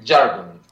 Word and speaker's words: Jargon: Yes, I'm Jargon, and the other one is George, the bass player, Pagidas. – George. So you say Jargon with like a hard J? – Yes, I pronounce it Jargon.